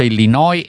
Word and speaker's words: Illinois: Quindi allora c'è una certa attenzione di linguisti, ci dicono primo che si pronuncia Illinois e senza la S, va bene, grazie Luigi, e poi che la pronuncia è PayPal e non PayPal, per PayPal.